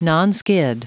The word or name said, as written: nonskid